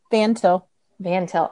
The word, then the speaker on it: Van Til: Van Til. – Okay. – Van, anybody like the thing you drive, and Til, like what you do to soil.